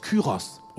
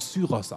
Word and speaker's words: Kyros: Dieser König, dieser gottlose König im Alten Testament, der Jerusalem aufbauen lässt, ist Kyros oder Zyros ausgesprochen.